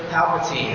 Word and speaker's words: Palpatine: So in Star Wars, the dragon would be Emperor Palpatine. Is that how his name's pronounced? It's been a while. Thank you, I see a nod in the front row.